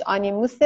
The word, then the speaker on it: آنیموس: آنیموس تلفظ درستش آنیموس